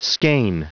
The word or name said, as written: skeane